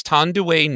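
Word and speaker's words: Thandiwe: She went back to the original spelling of her first name. – And the proper pronunciation then. – Yes. And we were like, well, wait, is that a typo? And then it made me look it up. No, she is Tondue Newton.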